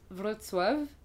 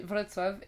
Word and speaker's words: Wrocław: Alors ça s'écrit W R O C L A W, donc W R O C A W. – Okay. – C'est L-A-W. Enfin, bref. En fait, c'est une orthographe un peu bizarre parce que c'est les lettres polonaises. Quoi, et genre le C, tu le prononces T, le L, c'est un L barré, donc c'est W. Bref, voilà, donc c'est Wrocław.